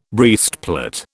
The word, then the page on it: breastplate: British English